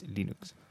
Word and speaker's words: Linux: My name is Linus, and I pronounce Linux as Linux.